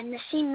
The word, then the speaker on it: Nassim: Yeah, Nassim Young Dragon. – And it's your first name spelled as an N-A-S-S-I-M, is that correct? – Yes, N-A-S-S-I-M.